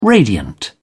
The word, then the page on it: radiant: British English